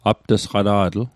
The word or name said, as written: apt'sqata'tl